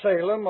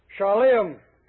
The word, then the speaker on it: Salem: Melchizedek, king of Salem, or Hebrew pronunciation, Shalem. Jerusalem. And I think some Jews greet one another, Shalem Molech. Peace, my child. The English pronunciation Salem, but you call it Shalem if you wish.